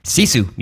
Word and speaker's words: Sisu: And of course, Sisu, is that how you say it? – Sisu, yes.